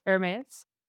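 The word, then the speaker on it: Hermès: and say it like the French, like the fashion house Hermes.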